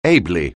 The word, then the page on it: ably: anglais